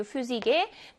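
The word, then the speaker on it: Physik: 물리학이라는 의미를 지닌 단어였던 이 Physik의 폐하도 F 발음을 내는 걸로 되어 있어요.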